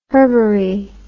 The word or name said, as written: herbary